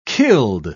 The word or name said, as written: killed